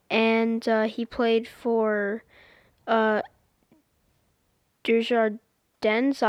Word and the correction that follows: Djurgården: IF, if I'm pronouncing that right. – I think you're referring to the Swedish team that he played for was Djurgården. – Yeah, Djurgården.